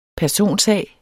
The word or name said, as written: personsag